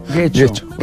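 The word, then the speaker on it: Getxo: Que no, Guecho, no, Guecho. – Guecho. Exacto, ahí es donde me equivoco yo, que lo vuelvo U.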